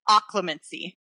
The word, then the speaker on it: Occlumency: I pronounce it Occlumency.